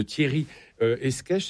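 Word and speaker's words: Escaich: Il s'agit de Thierry Esquèche, c'est comme ça qu'on le prononce.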